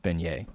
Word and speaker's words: beignet: The New Orleans food is pronounced beignet, by the way.